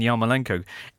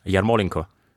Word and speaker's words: Yarmolenko: Yarmolenko. – That's how we should be saying it. – Yarmolenko. – Not Yarmolenko. It's the Yarmolenko. – You're stressing the second syllable as opposed to the third.